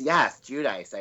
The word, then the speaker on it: Giudice: Yes, Giudice, I do. – Oh, that's right. She changed her pronunciation.